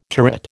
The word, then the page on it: turret: American English